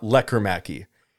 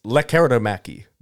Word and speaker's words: Lekkerimäki: Because it's not Lekkomäki. It's Lekkerimäki. There's like an I in there that we weren't pronouncing before. It's Lekkerimäki.